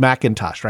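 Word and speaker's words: M'Intosh: Well, I was going to say, you can tell it's a delightfully old-timey case because McIntosh is spelled with the apostrophe instead of the C. So it's M apostrophe in Tosh, but it's pronounced McIntosh, right? – Interesting.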